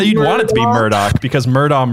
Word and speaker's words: Murdaugh: P-A-M Rebecca. Like what a douche. – Right. It's worse too. Cause I'm saying Murdaugh, but it's pronounced Murdaugh.